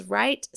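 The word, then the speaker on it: Wright: And then on Facebook in our wellness community, Encourage Wellness Right Community. And that is Right spelled with a W. And since that's my last name.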